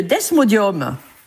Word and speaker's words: desmodium: une ampoule d'extrait de desmodium. C'est vraiment... Desmodium, comme ça se prononce. D-E-S-M-O-D-I-U-M.